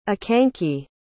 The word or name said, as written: akanke